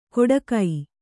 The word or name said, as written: ಕೊಡಗೈ